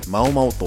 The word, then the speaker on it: MADMAO: MADMAO という記載で「まおまお」と読むんですよえっと、